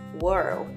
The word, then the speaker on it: whorl: Hair de cabelo, H-A-I-R, whirl, W-H-O-R-L, hair whirl. A pronúncia de whirl é bem parecida com world, mas sem o D no final. Outro termo também é o hair crown. Hair crown. Crown de coroa mesmo. C-R-O-W-N.